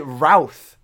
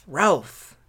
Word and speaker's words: Routh: How do you say it? Routh. – R-O-U-T-H. – Routh. – Ralph. – That's terrible.